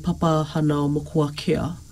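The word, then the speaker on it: Papahānaumokuākea: And so this marine area in Hawaii called Papahanao Mokuakea. Doesn't it sound so cool? – I know. Just as you said it, your pronunciation, bang on.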